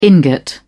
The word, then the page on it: ingot: British English